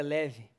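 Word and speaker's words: leve (לב): Coração vem de uma palavra hebraica, leb, mas pronuncia leve.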